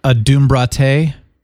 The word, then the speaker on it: adumbrate: How about It's pronounced adumbrate? – Adumbrate, yes. – Not adumbrate? – Correct. According to the all-knowing internet, it is adumbrate.